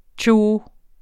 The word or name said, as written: tjo